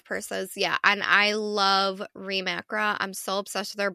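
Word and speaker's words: Reem Acra: I always pronounce it wrong. Reem Akra. It's Reem Akra.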